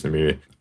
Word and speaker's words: Nymeria: Yeah, I'm pretty sure it's Nymeria.